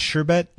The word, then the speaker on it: sherbet: You said sherbet? – Yep. That's the without the R? – That's the proper way, by the way.